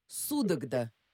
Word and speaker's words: Судогда: Вологда, но Судогда. Город Судогда действительно существует в Владимирской области.